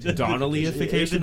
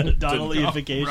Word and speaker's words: Donnellyification: Donnellification. – Because is that how you say it? – Donnellyification. – Donnellyification.